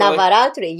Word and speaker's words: Navaratri: Navaratri, yes.